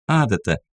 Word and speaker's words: адата: только на нашем языке он звучит как «адата», – сделала она ударение на первом «а».